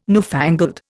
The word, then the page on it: newfangled: Indian